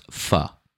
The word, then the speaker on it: pho: It's I think it's pronounced pho. – Pho? – Yes. But some people pronounce it pho. It's P-H-O.